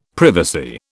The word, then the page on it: privacy: British English